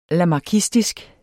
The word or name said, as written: lamarckistisk